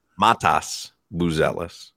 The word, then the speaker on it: Matas Buzelis: Montez Buzelis. That's how you say his name? – Matas Buzelis.